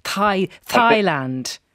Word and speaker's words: Thailand: Please stop pronouncing the H in Thomas, Thailand and the Thames River. Again, this might be over correction, Colm, would you say? Thailand.